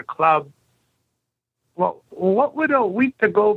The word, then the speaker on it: Wendigo: Wendigo is Wendigo, the way we pronounce it, Wendigo.